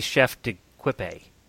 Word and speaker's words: chef d'équipe: I always mispronounce that. – I know. – It really should be chef d'équipe.